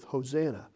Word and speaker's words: Hosanna: And all they did was they added an H on the front of it, and they accented the second syllable instead of the third, and that's the only changes they made. And when you do that, you come up with Hosanna.